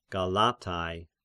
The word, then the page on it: Galatians: Galatai